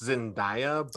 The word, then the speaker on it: Zendaya: How do you say it? Zendaya? – I think it's Zendaya. But, Zendaya. – But don't quote me.